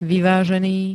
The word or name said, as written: vyvazeny